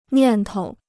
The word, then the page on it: 念头: niàn tou